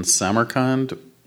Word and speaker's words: Samarkand: I wanted to ask you about Summer in Samarkand, which sure. I'm probably mispronouncing. – I think that's right.